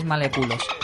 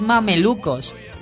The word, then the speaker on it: mamelucos: ¿Por qué tienes que llamarles maleculos? A mí me lo pronuncias mal. Es mamelucos.